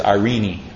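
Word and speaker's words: Irene (εἰρήνη): Actually, it's Irene. Long E at the end of Irene. Irene.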